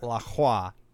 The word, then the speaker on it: LaCroix: It's definitely not LaCroix. It's pronounced LaCroix. Look at it again. Look at it again and tell me that you're pronouncing that right. It's La Hua. – Okay. I'll give you that. – I've never heard anyone say that it's actually La Hua, but whenever I hear LaCroix, I'm like, that's such a waste of so many letters.